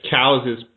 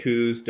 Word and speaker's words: Coos: Coos deer, cows deer, however you want to pronounce it. Cows is correct. – Yeah, I know. – But I say coos, Dar says coos, all the buddies I hunt with say coos, so whatever, coos, cows, they're unbelievable deer.